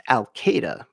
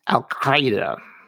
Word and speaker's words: Al-Qaeda: And the beginning sound is not a K, but a Q, making this almost impossible to pronounce. But it's pronounced Al-Qaeda. Well, sort of. That's how I pronounce it.